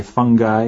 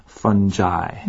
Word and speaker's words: fungi: We'll talk more about it in a little bit, but there are two pronunciations for this, right? So some people say fungi and some people say fungi. – Right, yeah. – Right. – So both are okay.